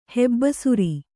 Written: ಹೆಬ್ಬಸುರಿ

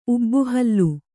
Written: ಊಬುಹುಲ್ಲು

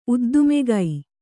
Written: ಉದ್ದುಮೆಗೈ

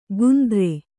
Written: ಗುಂದ್ರೆ

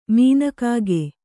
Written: ಮೀನಕಾಗೆ

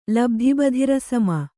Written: ಲಬ್ಧಿಬಧಿರಸಮ

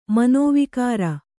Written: ಮನೋವಿಕಾರ

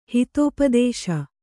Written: ಹಿತೋಪದೇಶ

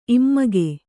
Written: ಇಮ್ಮಗೆ

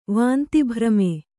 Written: ವಾಂತಿಭ್ರಮೆ

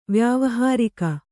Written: ವ್ಯಾವಹಾರಿಕ

